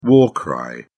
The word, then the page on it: war cry: British English